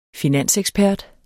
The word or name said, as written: finansekspert